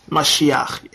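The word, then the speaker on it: Mashiach (משיח): How do you say it? Yeshua HaMashiach? – Yes. Mashiach, yeah.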